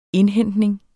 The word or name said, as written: indhentning